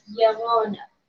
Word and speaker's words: Llorona: Well, it's two L's, so it makes a Y sound. So it's Llorona.